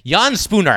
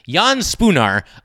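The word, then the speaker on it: Jan Špunar: And so for all those reasons, yeah, I think Jan Spoonma- I keep mispronouncing it. Jan Spunar. I keep trying to throw another consonant in there. Jan Spunar, I think, is a great pick for the Rainers at number five.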